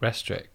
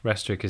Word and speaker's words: Restrick: It's Pete Restrick. Restrick is spelled R-E-S-T-R-I-C-K.